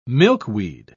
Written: milkweed

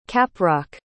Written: caprock

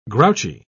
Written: grouchy

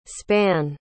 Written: spann

